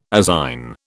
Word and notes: azine: British English

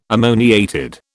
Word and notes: ammoniated: British English

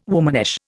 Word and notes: womanish: Indian

